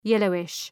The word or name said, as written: yellowish